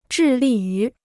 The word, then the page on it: 致力于: zhì lì yú